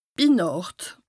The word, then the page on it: bi Nàcht: Bas-Rhin; Strasbourg